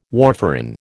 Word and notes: warfarin: American English

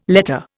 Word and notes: letter: Indian

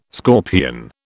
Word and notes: scorpion: British English

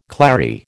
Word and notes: clary: American English

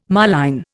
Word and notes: moline: Indian

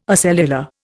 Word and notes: acellular: Indian

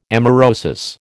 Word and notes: amaurosis: American English

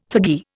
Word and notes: thuggee: Indian

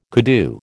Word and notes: koodoo: American English

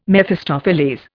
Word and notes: Mephistopheles: Indian

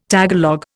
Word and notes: Tagalog: Indian

